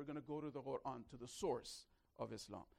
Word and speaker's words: Quran: Is it Quran or is it Quran? Quran, Qa, we don't have the letter in English. So the closest we have in the alphabet is Q. So it's the same book. I'm just pronouncing it the Arabic way.